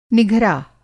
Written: निघरा

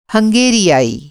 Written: हंगेरियाई

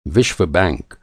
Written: विश्व

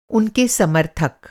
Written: समर्थक

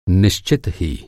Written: निश्चित

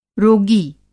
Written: रोगी

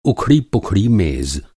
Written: उखड़ा-पुखड़ा

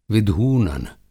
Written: विधूनन

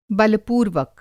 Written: बलपूर्वक

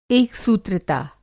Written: एकसूत्रता

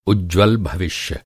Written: उज्ज्वल

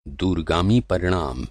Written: दूरगामी

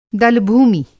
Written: दलभूमि

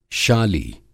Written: शाली

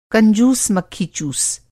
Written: कंजूस-मक्खीचूस

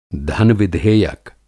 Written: धन-विधेयक

